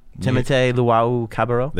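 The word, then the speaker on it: Timothé Luwawu-Cabarrot: Timotei Luau-Cabarrot.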